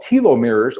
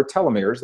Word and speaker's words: telomeres: They are called telomeres or telomeres. They're pronounced both ways.